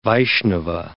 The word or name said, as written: Vaishnava